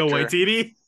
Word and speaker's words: Taika Waititi: Takeda Wahida, whatever his name is. Great director. – Taika Waititi.